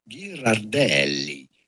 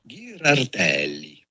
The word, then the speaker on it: Ghirardelli: In Italian, this would be said Ghirardelli. – Oh. – Ghirardelli. But it'd be fine in English or American to say Ghirardelli. Oh, it's accepted both ways.